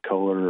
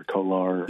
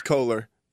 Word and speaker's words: Kohler: Is it Kohler or Kolar? – Yeah, it's Kohler. Yeah, I, I think it used originally they put it down as being Kolar, and then I think uh, he went to Mike Green and was like, "Yeah, you say my name is Kohler, just so you know," and it was wrong on the website.